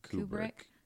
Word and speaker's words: Kubrick: Kubrick.